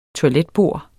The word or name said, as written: toiletbord